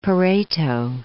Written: Pareto

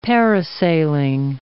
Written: parasailing

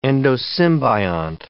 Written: endosymbiont

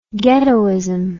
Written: ghettoism

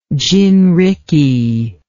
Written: gin rickey

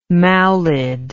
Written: mawlid

